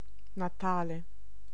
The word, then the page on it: birth: /bɜːθ/; /bɝθ/; /bɜː(ɹ)θ/